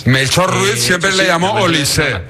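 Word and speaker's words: Olise: Oye, el otro día tuve una agria polémica con los compañeros porque tú me garantizaste que se pronuncia Olís y me pusieron cortes del propio jugador que dijo que es Olisé. – No, efectivamente se dice Olisé,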